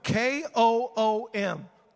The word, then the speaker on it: koom: In the Hebrew, the phrase get up means it's spelled this way K O O M, koom.